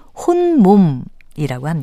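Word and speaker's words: 홑몸: 반면에 딸린 사람이 없는 혼자의 몸이라든가 아이를 베지 않은 몸이라는 뜻으로 말할 때 호 밑에 티읕 받침을 쓰고요. 발음은 혼몸이라고 합니다. 따라서 임신부에게 말할 때 혼몸도 아닌데 조심하세요 라고 말해야겠지요.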